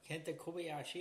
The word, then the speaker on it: Kenta Kobayashi: he yells Koba because that's a tribute to Kenta Kobayashi. – Yep, Kenta Kobayashi. Yep. – I said it right again.